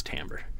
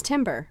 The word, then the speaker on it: timbre: timbre. – I thought it was supposed to be, oh, you're talking about like T-I-M-B-R-E. Mm-hmm. I thought that was timber. – No, it's timbre.